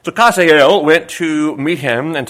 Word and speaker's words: Hazael: And the king said to Hazael, take a present in thine hand, Kazael, if you want to tell it the way it's pronounced,